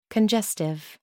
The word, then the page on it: congestive: American English